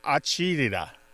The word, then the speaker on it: agita: Usually these days it is spelled A-G-I-T-A, but you might see it as arjita, A-R-G-A-D-A, or agita, A-G-I-D-A.